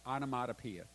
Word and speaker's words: onomatopoeia: The word murmured is this piece of trivia, but it's an onomana poetic word. Onomatopoeia. Thank you very much. I knew I wouldn't get in a syllable there somewhere.